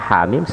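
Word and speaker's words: hamim (حم): Nah, di sini terdiri dari dua huruf, haknya dua harokat, dan mimnya 6 harokat maka cara membacanya hamim sekali lagi hamim nah, jadi sudah ada lima huruf makota.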